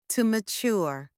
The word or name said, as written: to mature